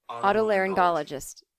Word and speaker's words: otolaryngologist: Otolaryngologist.